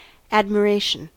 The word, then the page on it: admiration: American English